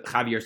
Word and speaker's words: Javier: J-A-V-I-E-R, but the way it's pronounced. Javier, which sounds very, very similar to Chivra.